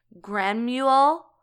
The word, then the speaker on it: Granuaile: I believe it's Granuaile, I think is how you say it.